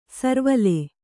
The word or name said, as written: ಸುರ್ವಾಲ್